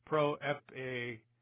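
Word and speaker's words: proepagaleta (προεπηγγείλατο): So verse 2, ha proepagaleta, I'm sorry, proepagaleta, there we go, lots of syllables there, diaton propheton autu en graphes hagias.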